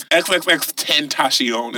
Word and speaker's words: XXXTentacion: T-E-N-T-A-C-I. – It's XXXTentacion is how you pronounce it.